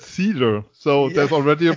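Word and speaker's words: Seider: I I I wasn't sure uh, which way was the proper pronunciation, and so uh, you know when Steve Yzerman called his name, he said cider,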